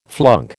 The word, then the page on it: flunk: American English